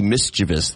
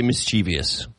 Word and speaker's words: mischievous: It's supposed to be mischievous. There's no I after the V. It's mischievous. – That's interesting. – I always say mischievous.